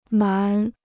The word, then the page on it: 蛮: mán